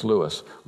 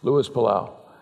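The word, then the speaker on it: Luis Palau: And maybe you pronounce it Louis. No, it's Louis. Louis Palau.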